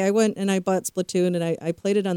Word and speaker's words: Splatoon: Am I saying that right? Splatoon, or is it Splatoon-y? – Yes, it's Splatoon.